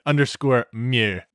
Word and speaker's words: mieux: I will put all that and then dash read by Ziglet _mieux. – Perfect. – And you got to pronounce that in French. Mieux.